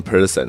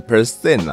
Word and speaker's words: percent: 啊，他有提到我练这个百分比的时候，常常念成 person 哦，好像是，我想一想，我之前常常念成 person，啊，应该是念错，应该是要练成 percent 啊，吼。